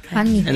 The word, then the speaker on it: pandiculación: Con acento en la O final. – Pandiculación.